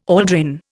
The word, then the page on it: aldrin: Indian